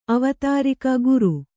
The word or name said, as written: ಅವತಾರಿಕ ಗುರು